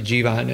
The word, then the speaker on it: Jeevan: And I know I've been mispronouncing Jeevan. His character's name is actually Jeevan in the show, played by Himesh Patel.